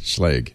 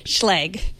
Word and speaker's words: Schlage: Is that how I pronounce it? – Schlage. – Schlage.